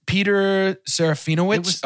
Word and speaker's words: Peter Serafinowicz: It was just Peter Serafinowicz.